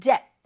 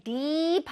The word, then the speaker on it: dip: 근데 이거 발음할 때 deep 하면 안 돼요. deep 하게 되면 깊은 이란 뜻이거든요. 근데 dip, dip 짧게 발음해줘야 돼.